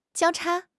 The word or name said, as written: 交叉